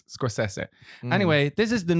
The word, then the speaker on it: Scorsese: that his name is pronounced Martin Scorsese.